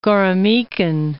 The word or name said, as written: Goremykin